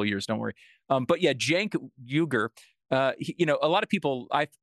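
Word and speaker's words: Cenk Uygur: Jenk. Jenk Uger. You pronounce it Jenk Uger. With apologies to right. Mr. Uger, because I've been butchering it here.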